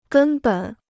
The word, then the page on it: 根本: gēn běn